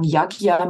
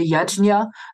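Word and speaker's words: yajña: Manche sagen Yagya, manche sagen Yagna, manche sagen Yajya. Ähm, ich sage Yajna, ähm, weil meiner Ansicht nach das zumindest ursprünglich mal die Aussprache war.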